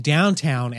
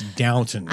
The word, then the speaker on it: Downton: You've been saying Downtown Abbey all morning, and I've been correcting you. It's Downton. – I know what it is. It's just we're from Texas. We don't say Downton.